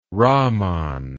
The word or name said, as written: rahman